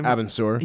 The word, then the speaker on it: Abin Sur: Abin Sur.